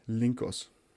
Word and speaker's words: Lincos: L-I-N-C-O-S, Linkos.